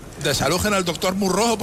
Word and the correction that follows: Moró: por favor. – ¡Es Moró, imbécil!